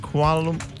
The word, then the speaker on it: Kuala Lumpur: Is it, Sarah, is it Kuala Lumpur? – Yeah, you said it right. – Kuala Lumpur? – Yep.